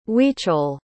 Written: huichol

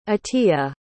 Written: atia

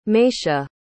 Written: meisha

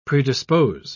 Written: predispose

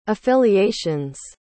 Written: affiliations

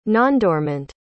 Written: nondormant

